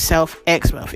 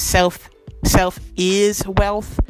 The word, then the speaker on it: Self X Wealth: And the name isn't pronounced Self X Wealth. It's pronounced Self Is Wealth, but it's spelled Self X Wealth, you know?